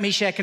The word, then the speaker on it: Meshach: Look at Daniel, Shadrach, Meshach, and Abednego. I always remember how to say the name: shake the bed, make the bed, and in the bed we go.